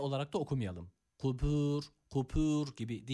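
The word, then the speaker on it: kubur (قبور): Kubur kelimesindeki kafa öncelikle dikkat edelim. Zaten şöyle bir okuyuş var. Fil kubur, kubur diye, kubur diye okunuyor. Yani kaftan bahsediyoruz öncelikle. Kaf harfi kef olarak okunabiliyor. Dikkat edelim. Burada kef harfi yok. Kaf harfi var.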